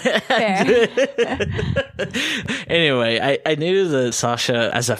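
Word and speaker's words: Sacha: It's okay. It's pronounced Sasha. Okay. I was like, when I saw the C, I was like, I've been saying that wrong.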